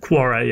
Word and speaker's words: Quora: How do you pronounce that? – Quora, yeah.